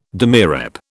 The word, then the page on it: demirep: British English